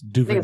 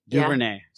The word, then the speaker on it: DuVernay: It's DuVernay. – I think it's DuVernay. – DuVernay. – Yeah. Yeah, just faster.